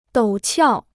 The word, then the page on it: 陡峭: dǒu qiào